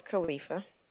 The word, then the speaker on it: Khalifa: My name is Khalifa.